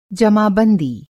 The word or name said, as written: जमा-बंदी